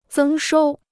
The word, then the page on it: 增收: zēng shōu